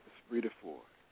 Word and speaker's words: Esprit de Force: It's called Esprit de Force, and please correct me if I'm not pronouncing it correctly. Is that the correct title for the CD? – Yes, Esprit de Force.